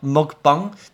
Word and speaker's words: mukbang: 吃水饺，看吃一百个之类的，吃播这个东西叫 mukbang。其实这个发音怪怪，对不对？因为这个发音其实来自于韩文 m u k b a n g。